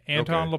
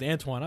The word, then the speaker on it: Anton: Did I say that right? – Yeah, Anton okay. LaPlatte. – I keep wanting to put a W.